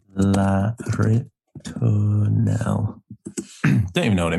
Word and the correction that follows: La Ritournelle: La Ritournelle.